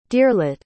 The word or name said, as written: deerlet